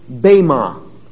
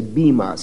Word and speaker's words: bema: That word judgment seat is the translation of one short Greek word, pronounced bema. Or in English, transliterated in English, we would simply say bima, since it's an E.